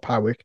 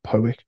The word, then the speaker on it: Powick: as I should say, Powick Bridge, not Powick. The locals pronounce it Powick.